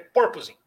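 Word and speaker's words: porpoising: Por, proposing? – É, eu estava falando errado, mas a, a, a, a, a ênfase, é como se o acento circunflexo fosse na primeira sílaba. É purposing.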